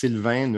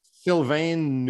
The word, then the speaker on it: Sylvain: So there's the nasal N in Sylvain and the U in Neuvel. So my name is sort of supposed to be pronounced Sylvain Neuvel, but no one does that. And when they try, it's usually worse. So that's, I've gotten used to Sylvain Neuvel. And that's how I introduce myself now.